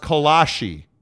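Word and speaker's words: kolache: Dallin says, in the Czech Republic, where it is from, it's pronounced Kolak. Colon? In America, it's pronounced Kolashi.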